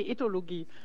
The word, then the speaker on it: Ethologie: Sie sind Professorin für kognitive Ethnologie. Habe ich das richtig gesagt? – Nee, Ethologie. – Ethologie. – Genau, ohne das N.